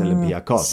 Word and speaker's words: Olympiakos: When you say anything Greek, you emphasize the last syllable. So it's Olympiakos.